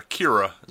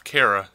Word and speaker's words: Kara: Yeah, but she still keeps calling her Kira instead of Kara.